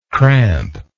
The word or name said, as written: crampingly